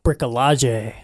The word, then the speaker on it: bricolage: Am I pronouncing it correctly? – Or is it French of bricolage? – Bricolage.